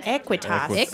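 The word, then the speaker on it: Aequitas: And am I saying this right? Aquitas? Equitas? – Equitas. – Aquitas Veritas. – A-E. The lovely blended character.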